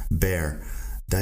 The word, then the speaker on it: beard: Fíjate en el diptongo ahí, beer, ear el diptongo, ia. Así que la palabra beard, tienes que pronunciar la B, la R, la D, y luego el diptongo en medio, ia, beard.